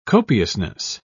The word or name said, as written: copiousness